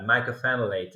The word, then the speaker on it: mycophenolate: You have pronounced it very well, yeah, mycophenolate.